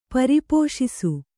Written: ಪರಿಪೋಷಿಸು

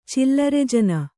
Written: ಚಿಲ್ಲರೆಜನ

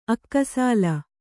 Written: ಅಕ್ಕಸಾಲ

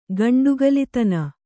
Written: ಗಂಡುಗಲಿತನ

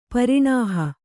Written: ಪರಿಣಾಹ

